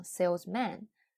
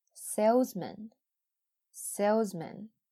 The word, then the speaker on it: salesman: 它的元音是梅花 i，但是在 salesman 里边，那它的重它不在重音上，所以呢，它不再是梅花 i，一定要注意不要念成 salesman，很多同学都会发错。正确的是 salesman，salesman。